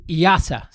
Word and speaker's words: IIASA: It is IASA. So it's IIASA, but it's pronounced IASA.